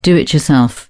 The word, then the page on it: do-it-yourself: British English